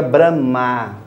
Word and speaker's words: Brahma: Em sânscrito, a gente pronuncia Brahma com o A longo.